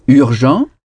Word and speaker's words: urgent: Urgent. Urgent. – Urgent. – Urgent. Urgent. Ça s'écrit de la même façon qu'en français, mais UR en anglais se prononce UR. Ce mot se prononce urgent.